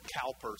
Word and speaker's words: Cowper: In fact, his name is spelled Cowper, C-O-W-P-E-R, if you look it up. But Cowper, he was clinically depressed.